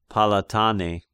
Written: Palatane

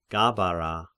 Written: Gabara

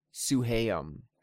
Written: Suhaym

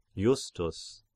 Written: Justus

